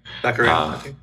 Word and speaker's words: Baccarin: Baccarin.